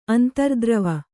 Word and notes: ಅಂತರ್ದ್ರವ: kannada

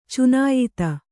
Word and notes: ಚುನಾಯಿತ: cunāyita

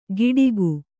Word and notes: ಗಿಡಿಗು: kannada

